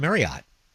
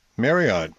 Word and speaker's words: Marriott: Marriott. – Ending in like O-T-T, but that's not the right pronunciation, dude. It's supposed to be Marriott. – Marriott. – Like chariot. So it's not Marriott, it's Marriott.